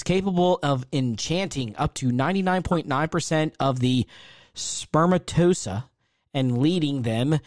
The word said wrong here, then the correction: spermatozoa: Spermatozoa. – Is that how you say it? – Spermatozoa, yep.